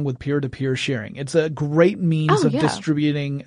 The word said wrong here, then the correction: distributing: Distributing large files. I put the wrong emphasis on the wrong syllable.